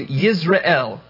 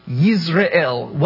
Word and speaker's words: Yizre'el: So first we look at he has a son, and that son is to be named Yizre'el, now, that sounds very much like Yisrael, and in fact, the pronunciation is very close, but they're spelled differently.